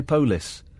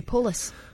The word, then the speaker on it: polis: I can't do it the way the Scottish say polis. Polis. I love it. Limmy does it beautifully. – The polis. – The polis. It's just, and it's spelled P-O-L-I-S, and it's just such a delicious turn of phrase, and love it.